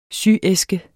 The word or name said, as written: syæske